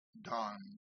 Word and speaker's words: dan: The syllable for a D grace note on low G is D-A-M, and it's pronounced dom. And a D grace note on low A is D-A-N, don.